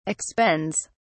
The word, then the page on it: expends: British English